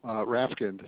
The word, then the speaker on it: Rafkind: First name is Daniel, D-A-N-I-E-L. – And what's your last name, Daniel? – Rafkind. It's spelled R-A, F like in Frank, K-I-N-D, like in Delta. – So that's R-A-F, K as in kilo, I-N-D as in dog.